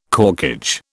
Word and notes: corkage: British English

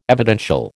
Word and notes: evidential: American English

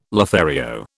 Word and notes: Lothario: British English

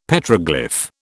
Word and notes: Petroglyph: British English

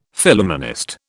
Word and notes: Phillumenist: British English

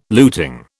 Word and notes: luting: British English

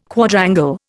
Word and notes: Quadrangle: Indian

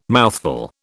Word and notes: mouthful: British English

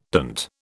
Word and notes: dint: British English

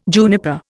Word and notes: juniper: Indian

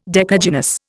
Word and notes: decagynous: Indian

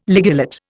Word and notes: ligulate: Indian

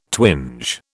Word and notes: twinge: British English

